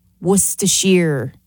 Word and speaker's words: Worcestershire: And also, Worcester sauce, Worcestershire is how you pronounce it.